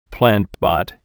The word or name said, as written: plant pot